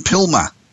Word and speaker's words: PILMA: Paul is active in the legal marketing industry and was inducted to the PILMA. Is that how you say it? – That is correct.